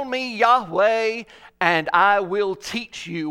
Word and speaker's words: Yahweh: There are no vowels in Hebrew, only consonants, so we guess at the pronunciation. Our best guess is Yahweh, often translated in our Bibles as the Lord or I am who I am.